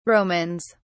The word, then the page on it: romans: British English